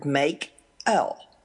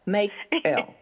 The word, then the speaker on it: Makell: Well, actually, it's very straightforward. Makell. Makell.